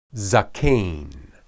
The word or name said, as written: zaqen